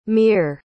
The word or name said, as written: mear